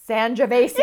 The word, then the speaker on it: Sangiovese: It rhymes with Schmandrovesi. – Sangiovese.